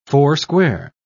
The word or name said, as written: foursquare